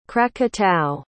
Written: krakatau

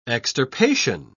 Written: extirpation

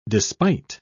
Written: despite

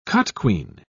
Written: cotquean

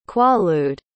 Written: quaalude

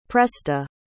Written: presta

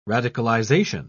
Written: radicalization

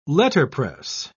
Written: letterpress

